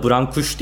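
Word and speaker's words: Brâncuși: Bu arada Brankuş diye okunuyor adı.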